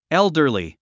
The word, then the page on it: elderly: éldə r li; エルダリー